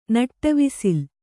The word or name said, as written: ನಟ್ಟವಿಸಿಲ್